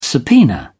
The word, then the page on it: subpoena: British English